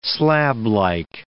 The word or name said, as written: slablike